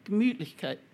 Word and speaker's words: Gemütlichkeit: Welcoming, Gemütlichkeit.